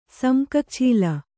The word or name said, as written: ಸಮ್ಕಕ್ಛೀಲ